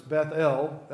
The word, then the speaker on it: Bethel: Now, I pronounce it that way on purpose, Bethel. That would be the Hebrew pronunciation, but also that kind of gives the meaning of the word. Beth is the word for house, and then El is the name of God, the short form.